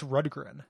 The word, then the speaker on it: Rundgren: It's Rundgren.